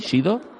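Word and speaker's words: Sheedo: ¿Se pronuncia así, Shido? – Sí, se pronuncia, se deletrea un poco diferente, pero... – SH, dos E's y una D y una O.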